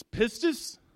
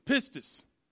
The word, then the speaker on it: pistis: It's pistis. And believe me, I listened to it like 10 or 15 times because I wanted to make sure that I said it right. It's pistis,